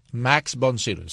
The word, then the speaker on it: Max von Sydow: Max von Sydow, sabía que lo había dicho bien.